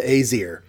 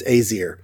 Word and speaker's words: Aesir: It's spelled A-E, but it's like a combined A-E. – Right. – S-I-R. And normally when I see an A-E in an ancient word, I tend to go with I because that's the Roman Greek version. But this is Norse, so different pronunciation rules. And he says it's Aesir.